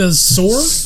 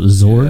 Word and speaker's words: Szohr: It's Jessica Zohr. – Zohr? Yeah. – S-Z-O-H-R.